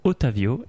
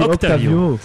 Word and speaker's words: Otavio: À noter que depuis 21h, Marc a réussi à prononcer le mot Otavio et non Octavio.